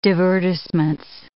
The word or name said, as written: divertissements